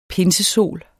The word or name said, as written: pinsesol